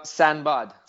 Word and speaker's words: Sinbad: Sinbad. – Sinbad.